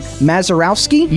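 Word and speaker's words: Mazeroski: Bill, I'm going to butcher the last name, Mazurowski. – You got it.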